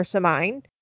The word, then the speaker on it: Samhain: So Halloween originated with the ancient Celtic festival of, oh my God, I'm going to butcher this, Samhain, which is spelled S-A-M-H-A-I-N. So I always try to say Samhain or Samhain, but it's Samhain.